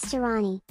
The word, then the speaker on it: Tirani: My name is Tirani. – How do you spell that? – T-I-R-A-N-I.